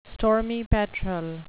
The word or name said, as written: stormy petrel